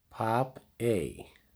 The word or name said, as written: Papey